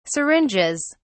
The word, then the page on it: syringes: British English